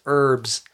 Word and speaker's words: herbs: E R B S. Herbs. But it's with an H.